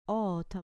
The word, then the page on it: ota: grec